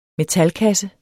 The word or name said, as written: metalkasse